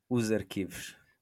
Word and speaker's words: arquivos: In the run-up to the European Championships, we decided to dig into the archives or archives, or if wants to give the really perfect pronunciation in Portuguese. – Os arquivos. – Oh, so smooth, so smooth.